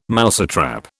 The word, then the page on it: mousetrap: British English